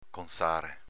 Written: conzare

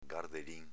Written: gardelin

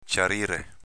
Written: ciarire

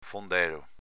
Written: fondelo

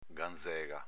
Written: ganzega

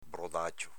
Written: brodacio

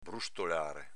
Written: brustolare

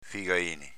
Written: figaini

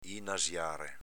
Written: inasiare

